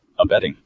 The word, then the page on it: abetting: American English